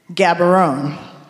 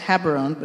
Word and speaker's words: Gaborone: And I say, mispronouncing it, Gaborone. He said, close, it's Haberone, but okay.